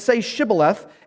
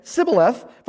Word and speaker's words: Shibboleth: And because of where they had been raised and the area where they were, they could not pronounce the H in Shibboleth. They pronounced it Sibboleth.